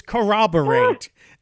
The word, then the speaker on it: corroborate: And I'm like, where, where, where is the W in that word? I don't know where that sneaks in there. It's corroborate.